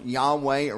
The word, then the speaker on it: Yahweh: I mean, it's Yod-Heh-Vav-Heh, pronounced Yahweh originally.